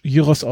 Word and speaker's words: Gyros: Und ähm, aus Griechenland, also Shawarma ist dann mit Rindfleisch und aus Griechenland gibt's dann den Gyros, oder wir kennen als Gyros, aber neugriechisch Gyros ausgesprochen, ähm, mit Schweinefleisch.